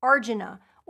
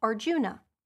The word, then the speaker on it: Arjuna: It is in the Gita that the unifying principles of samsara are carefully explained by Krishna in his conversation with the warrior prince Arjuna, or sometimes pronounced Arjuna.